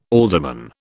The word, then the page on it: alderman: British English